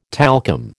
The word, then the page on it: talcum: American English